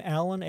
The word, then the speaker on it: Alan: My middle name, Alan, A-L-A-N.